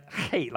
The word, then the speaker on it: Chet: and the number for 8 is it's C-H-E-T, but it's pronounced hate, like you hate somebody, but you hate them with the hairball in your throat.